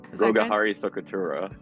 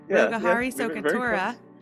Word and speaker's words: Rogahari Sokatura: Rogarhi Sokatora. – Rogarhi Sokatora, which means healer of the brokenhearted.